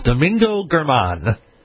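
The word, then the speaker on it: Domingo Germán: It is Domingo German. – Domingo German? German. – Yeah, I'm on with you. It's Domingo German.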